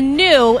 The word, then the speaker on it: gnu: A new, and it's spelled G-N-U, is an antelope.